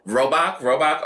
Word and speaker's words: Robach: So if you don't know, TJ Holmes, Amy, how do you pronounce her last name? – Robach, okay.